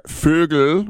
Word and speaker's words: Vogel: They have now said today it's Frank Vogel, or Vogel, which means bird in German. Vogel. The V is pronounced like an F and the W is pronounced like a V.